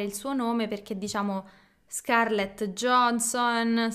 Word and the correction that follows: Scarlett Johansson: Scarlett Johansson, Scar- Scarlett Johansson? È difficile per gli italiani.